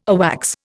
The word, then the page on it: AWACS: Indian